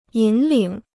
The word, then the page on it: 引领: yǐn lǐng